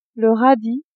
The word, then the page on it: radis: female